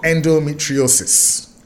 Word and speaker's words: endometriosis: Endometriosis.